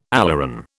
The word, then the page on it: aleuron: British English